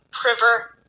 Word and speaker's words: Priver: And on Facebook, I'm Sue Priver Coach.